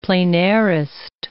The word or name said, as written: pleinairist